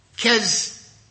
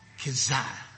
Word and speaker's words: kezazah: It is the word K-E-Z-A-Z-A-H. This Irishman reads it as Keza. How's that?